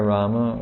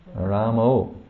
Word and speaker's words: Rama: Some people say Hare Rama with an O. – Ramo. – Ramo. – Prabhupada said, "Who is this Ramo?"